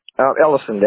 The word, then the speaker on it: Ellison: Ellison. That's E-L-L-I-S-O-N. – Ellison, E-L-L-I-S-O-N.